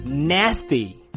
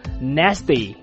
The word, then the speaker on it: nasty: 来看一下，跟我一起念 Nasty Nasty Nasty。当然最后这个 T 呢，你也可以浊化成 D，你念成 Nasty Nasty Nasty。